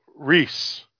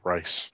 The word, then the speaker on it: Rhys: And that was by that was the actor Paul Rhys. I'm saying it correctly this time, the Welshman. Rhys, not Rice. Or Rise.